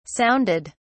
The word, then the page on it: sounded: British English